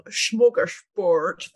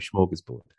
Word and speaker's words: smorgasbord: I very much admired your pronunciation of smorgasbord there. – Oh, no. Listen, I grew up in Stockholm, so that's the outside of Stockholm pronunciation of smorgasbord.